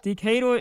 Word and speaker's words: Decatur: A was Decatur, Illinois. I might be saying it wrong. – Oh, yeah. Decatur, yeah, it was that. Decatur is right, exactly.